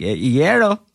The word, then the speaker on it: Iero: I think that's actually the correct pronunciation of like when they first came over. It was supposed to be a Iero. Kind of thing, but then somehow it turned into Iero, which is how my family now pronounces it, and then my professional name is Frank Iero.